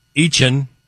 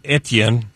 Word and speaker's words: Etienne: And in the running back department, Etienne, who used to be Etienne.